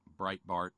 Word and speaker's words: Breitbart: This included things like Breitbart, This included things like that website that rhymes with light and fart, and Alex Jones's website.